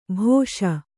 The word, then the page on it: ಭೇಷ್: kannada